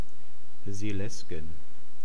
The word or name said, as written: Seelaesgen